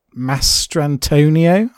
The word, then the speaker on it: Mastrantonio: But then you've got Mary Elizabeth Mastriano. Mastrantonio, I think it's pronounced as Maid Marian.